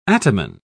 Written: ataman

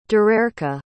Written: darerca

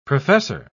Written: professor

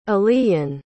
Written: aliyn

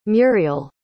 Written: muriel

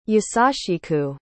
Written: yasashiku

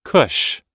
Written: cush

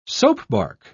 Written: soapbark

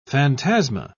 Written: phantasma

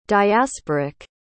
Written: diasporic

